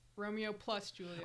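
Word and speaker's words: Romeo + Juliet: Romeo plus Juliet.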